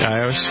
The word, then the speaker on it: iOS: Doing so, there's a mobile app there using Richie, and there's also let's see, the app which is free for iOS, and is that's how you say it, right? iOS. – Yeah, iOS.